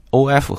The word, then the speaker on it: of: 那么谈论这个发音啊，一定要注意一下这两个 OF 和 OFF。 OF 呢发 of 啊，注意 F 呢在这里发的是 V 这个音 of，但是呢如果是 OFF，那前面的这个 O 呢首先是一个长音的 O，然后后面是 F 的音啊，所以是 off well off their planned course，所以注意一下这个发音的变化和区别。